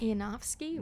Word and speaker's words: Yanofsky: The girl's voice, Nikki, cannot pronounce this, so I'm gonna spell it: YANOFSKY. Yanofsky? – Wow. – Nikki Yanofsky.